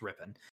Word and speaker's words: Rippon: You think given Rippon, I keep wanting to add that extra syllable. I know it's not. I've been told a million times it's Rippon.